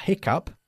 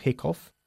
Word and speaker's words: hiccup: A hiccup. Fíjate cómo se pronuncia. Hiccup y no hiccough. Hiccup.